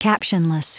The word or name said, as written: captionless